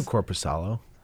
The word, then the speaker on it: Korpisalo: It's just Merzlikins? What is it? – Jonas Korpisalo and Elvis Merzlikins. – It's Korpisalo and it's Merzlikins. – I knew Korpisalo.